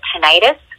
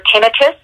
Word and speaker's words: tinnitus: And I think you touched on something that I didn't talk about, was along with this, you also get tinnitus or tinnitus. Some people pronounce it differently, which is ringing in your ear, the ear that is, you can't hear out of.